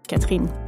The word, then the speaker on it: Catherine: I love the fact that I can speak French because it means that I can pronounce my own name, Catherine.